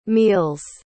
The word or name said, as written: meals